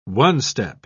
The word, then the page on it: one-step: British English